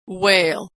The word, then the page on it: wale: w